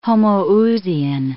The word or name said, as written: homoousian